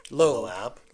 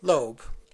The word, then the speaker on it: Loab: Just a few characters that said L-O-A-B. Loeb. Loeb. She decided to pronounce it Loeb.